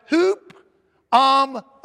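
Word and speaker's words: hupomone (ὑπομονή): It comes from the Greek word hupomone, and it's spelled hypermone, hypermone, but it's pronounced hupomone, and it means to endure, to continue.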